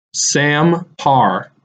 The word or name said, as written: Sam Parr